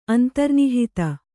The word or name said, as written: ಅಂತರ್ನಿಹಿತ